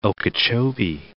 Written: Okeechobee, Lake

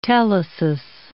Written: telesis

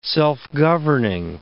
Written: self-governing

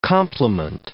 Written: complement

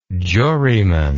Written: juryman

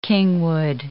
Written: kingwood

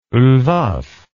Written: Lvov

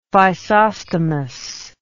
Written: physostomous

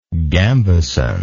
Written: gambeson

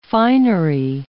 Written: finery

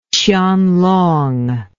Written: qianlong

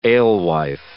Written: alewife

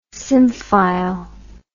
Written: symphile

